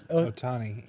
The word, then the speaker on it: Ohtani: Ohana Stare. Shohei Ohtani. – Yeah. – Did I say that right? Isn't that where it is? – Ohtani.